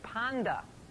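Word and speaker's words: panda (πάντα): The Greek word is panda. You would spell it just like panda, panda bear, P-A-N-D-A. That's how it's pronounced.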